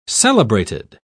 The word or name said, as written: celebrated